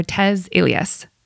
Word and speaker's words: Tez Ilyas: I'd like to welcome our guest comedian, actor, and as of a month ago, published author Tez Ilyas.